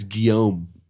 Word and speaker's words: Guillaume: Well, it's Guillaume.